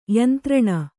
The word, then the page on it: ಯಂತ್ರಣ: Kannada